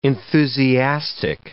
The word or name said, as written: enthusiastic